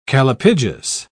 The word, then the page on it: callipygous: British English